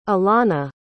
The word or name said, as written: alonna